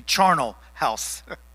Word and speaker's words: charnel: I'm sorry, my wife corrected me on how to pronounce that. It would be charnel house.